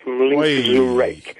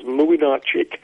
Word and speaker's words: Młynarczyk: I will never forget doing a game of Poland's in the 1982 World Cup finals, and their goalkeeper was spelt M-L-Y-N-C-Z-A-R-E-K. Looked like Mlinczurek. So another part of the preparation, you get hold of somebody from Poland on that instance, say, how on earth do you pronounce this man's name? And it was Młynarczyk. It didn't look a bit like it sounded, but you have to do that.